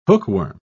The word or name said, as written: hookworm